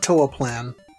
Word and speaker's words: Toaplan: Or do you pronounce it Toaplan? – I always call it Toaplan.